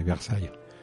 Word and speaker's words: Versailles: ¿Cómo se pronuncia Versalles en francés? – Pues es muy parecido. Se dice Versailles. – Versailles. Bueno, lo he pronunciado prácticamente igual.